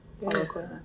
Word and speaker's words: Coelho: It was The Alchemist by Paulo Coelho. – Coelho. Is that how you pronounce it? – Coelho.